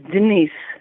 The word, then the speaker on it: Denise: My name is Denise. – Janice. – No, D-E-N-I-S-E. Yes.